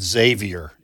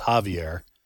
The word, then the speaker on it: Xavier: While pronouncing Health and Human Services Secretary Becerra's last name correctly, I did pronounce his first name incorrectly and said Xavier. It's Javier because he's Hispanic.